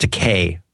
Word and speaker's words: Takei: Well, I think no, I think you're, I think you're thinking of uh, Leonard Nimoy, pronounced decay, rhymes with DK.